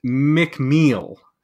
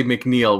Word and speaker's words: McMeel: How is it pronounced? I always get it wrong. It's the Andrews McMeel Syndicate. – I think Andrews McMeel. I think it used to be Universal, and I'm not sure about the corporate ownership, but I believe it's Andrews McMeel. – I used to always say McNeil, which is probably why we never got syndicated.